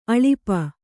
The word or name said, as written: ಅಳುಪ